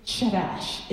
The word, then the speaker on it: chadash: So the Hebrew word for new is chadash. It's spelled C-H-A-D-A-S-H, and it means renew, repair, or restore.